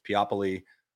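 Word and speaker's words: Peopoly: It looks like people, but it's Piopoli L, which is large.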